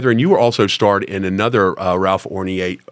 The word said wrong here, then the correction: Odierna: I always pronounce it. Odierna. – Odierna, and he's a good pal of mine, but I always say Ralph, I pronounce it wrong.